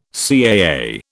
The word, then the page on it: CAA: British English